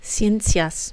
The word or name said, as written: ciencias